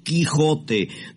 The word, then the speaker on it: Quijote: un tal don Jicote, la cosa, Jicote. Don Jicote del Manchacho. – No, Dios mío, Genara... No, por favor, no se dice don Jicote, se dice don Quijote de la Mancha, la obra del ilustre don Miguel de Cervantes.